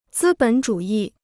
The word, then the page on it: 资本主义: zī běn zhǔ yì